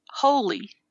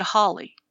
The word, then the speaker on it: holy: The word was holy. And I said, Holly.